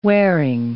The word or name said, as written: wearing